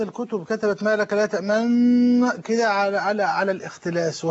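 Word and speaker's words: تأمنّا: يعني اصل كلمه تامنا تامنونا ولتخفيف النطق قرأ النبي صلى الله عليه وسلم تامنا وقرأ النبي صلى الله عليه وسلم ايه باختلاس.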